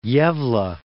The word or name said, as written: Gavle